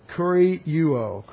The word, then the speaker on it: kurieuo: It's not a very common word, only 7 times in the New Testament, and it's kurieuo, k-u-r-i-e-u-o, k-u-r-i-e-u, long O, kurieuo.